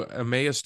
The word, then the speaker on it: Emmaus: The two Emmaus disciples, did I say that right? – Yep, Emmaus disciples in Luke 24:13 through 32.